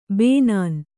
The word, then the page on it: ಬನೀನು: banīnu